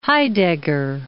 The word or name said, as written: Heidegger